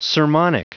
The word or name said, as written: sermonic